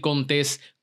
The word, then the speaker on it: Contés: Mi nombre es Miguel Contés, con acento en la E.